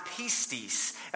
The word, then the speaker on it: pistis: Now, the Greek word for belief in the New Testament in relation to what we do is pronounced pistis.